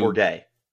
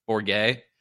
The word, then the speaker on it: Bourguet: And then you have Trenton Borgo. Is that how you say his name? Who Bourguet. – Bourguet.